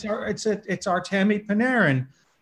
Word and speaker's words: Artemi Panarin: That's the Artemi Panarin. Am I saying this right, Mr. Hockey Expert? – Obviously, you're obviously not a hockey fanatic. It is. You got it right. It's Artemi Panarin.